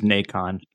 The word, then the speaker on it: Nacon: They were acquired by, I keep forgetting how to pronounce this, Nason, the French publishing agency that ended up grabbing Spiders. – I think it is Nacon.